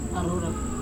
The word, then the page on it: arora: Serewen